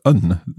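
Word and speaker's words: an: Oh, so it's un, the same as the masculine nouns we've learned. So un station, but also un skull. – That's right. – And that un is spelled A-N.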